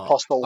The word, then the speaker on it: Apostle: But so I watched Apostle yesterday, and that was you don't pronounce Apostle. The T. Yeah, you don't. – Oh, do you not? Apostle.